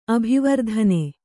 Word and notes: ಅಭಿವರ್ಧನೆ: abhivardhane

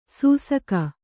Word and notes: ಸೂಸಕ: sūsaka

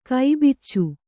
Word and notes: ಕೈಬಾಚಿ: kai bāci

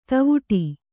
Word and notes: ಧವಡ: Kannada

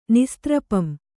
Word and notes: ನಿಸ್ತ್ರಪಂ: Kannada

